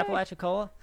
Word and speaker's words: Apalachicola: The white, they're kind of Atlantic and Gulf Coast drainages of North America, ranging from Hudson River in New York to the Apalachicola River in Florida. I say that right? – Yeah. Apalachicola.